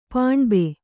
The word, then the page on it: ಪಾಣ್ಬ: Kannada